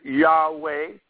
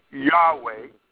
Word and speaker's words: Yahweh: So that word Yud Hey Vav Hey is also pronounced Jehovah. Some people pronounce it Yahweh. Some people pronounce it Yahweh.